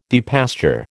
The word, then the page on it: depasture: American English